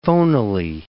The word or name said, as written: phonily